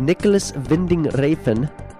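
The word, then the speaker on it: Nicolas Winding Refn: Nicholas Winding Refn. That's the correct pronunciation there.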